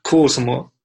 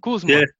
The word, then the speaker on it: Kuusamo: So you're from Finland, Lapland, from Kwasuma. I pronounced that right? – Yeah, almost there. It was Kuusamo. – Kuusamo. Yeah, almost had it.